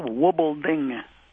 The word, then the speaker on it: Wubbolding: Okay, the accent's on the first syllable, Wubelding.